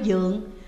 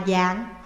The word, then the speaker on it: dượng: Chữ dương này đồng âm với chữ dương, họ của vua Tùy, lại cùng đồng âm với dượng, dượng cũng đọc là dạng hoặc là dương.